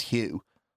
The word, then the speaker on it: Huw: At least it's Hugh. – I've never seen a Hugh spelled like that before. – Hugh is H-U-W for the people listening at home.